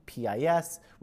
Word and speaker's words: PiS: often known by its Polish acronym, PIS, which is usually pronounced as PiS.